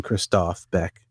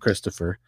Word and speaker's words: Christoph Beck: I think you pronounce his name Christoph Beck. – Yeah. – It's not Christopher.